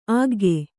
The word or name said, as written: ಆಗ್ಗೆ